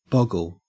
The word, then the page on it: boggle: Australian English